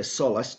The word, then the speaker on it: Solas: It happens in Solas, or apparently in Gaelic you say Solas, to rhyme with William Wallace, I remind myself.